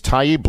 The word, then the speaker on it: Taibbi: Everybody should go check out his Taibbi blog, right? Is that how I would pronounce that, right? – Yep.